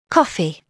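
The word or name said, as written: coffee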